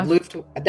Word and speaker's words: Luftwaffe: They were originally part of a Luftwaffe station. I'm not sure if I'm going to go with you on that Luftwaffe. Yeah, Luftwaffe. That's what I would say, okay.